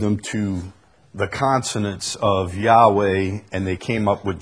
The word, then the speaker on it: Yahweh: The Hebrew letters YHWH, Yod, He, Wah, He, YHWH, is probably best pronounced Yahweh.